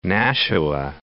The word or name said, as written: Nashua